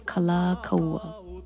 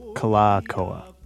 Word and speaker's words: Kalakaua: Is it Kalakaua? – So it's Kalakaua. – Kalakaua.